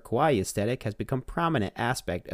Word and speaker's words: kawaii: Kawaii. Pronounced kawaii, like I pronounced it.